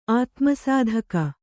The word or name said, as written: ಆತ್ಮಸಾಧಕ